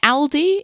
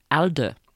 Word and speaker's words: ALDE: I've been calling it ALDE in my head, like the German supermarket. But I looked at some YouTube videos to check the pronunciation, and most people say ALDE. So there you go, ALDE.